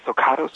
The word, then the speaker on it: Socatos: Yeah, the T is silent. – Yes. – Yeah. – Socatos.